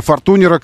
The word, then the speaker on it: Fortuner: Toyota Fortuner, возможно, кому-то сейчас рубануло по уху, резануло произношение, но, тем не менее, парни в российском офисе Toyota настаивают, что это и есть каноническое произношение названия Fortuner, который был Fortuner для вас всегда.